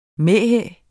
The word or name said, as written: mæhæ